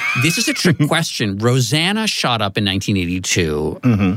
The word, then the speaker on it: Rosanna: But Patricia pointed out to me that her sister's name is really pronounced Rosanna.